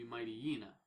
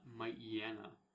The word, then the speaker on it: Mightyena: So it'd be Mightyena, not Mightyena, because it's not a Mighty...